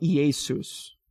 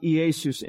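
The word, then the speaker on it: Iesus (Ἰησοῦς): And so in the Greek, the name Jesus is pronounced like Iesus. All right? It's pronounced Iesus in the Greek.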